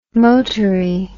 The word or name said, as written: motory